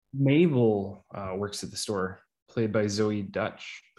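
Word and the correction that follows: Deutch: It's actually pronounced Deutsch, just so you know.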